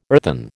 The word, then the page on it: earthen: American English